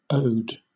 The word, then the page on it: ode: British English